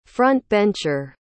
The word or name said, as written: front-bencher